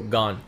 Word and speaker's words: Gane: Say it with me, bro. Gone.